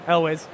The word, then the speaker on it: Elwes: Carrie Elwes. I never know how to say his name. – Elwes. – Yeah, Elwes.